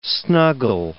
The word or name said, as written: snuggle